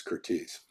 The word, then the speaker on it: Curtiz: But everyone from there on, except for him and probably his family, pronounced his surname as Curtiz.